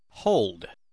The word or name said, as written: hold